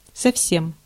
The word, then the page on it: совсем: IPA: /səfˈsʲem/